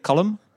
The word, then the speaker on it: Colm: Hopefully I'm saying your name right. I've listened to it enough times. – Give it to me one more time. – Colin. – Column, yeah.